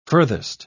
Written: furthest

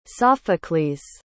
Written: Sophocles

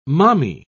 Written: mommy

